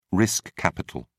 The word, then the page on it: risk capital: British English